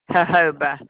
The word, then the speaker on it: jojoba: I'm not sure if I'm pronouncing it right, so I'm just going to spell it J-O-J-O-B-A. – Jojoba. Is you put the H, and the H sound is where the J's are.